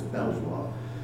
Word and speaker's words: Belvoir: I know it should be pronounced Beaver, but I don't think a lot of people got Beaver, so it has to be pronounced as Belvoir.